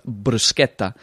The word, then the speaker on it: bruschetta: And then we were all laughing because obviously she's Italian, and it's pronounced bruschetta, like the SCH is sk.